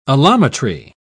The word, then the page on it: allometry: British English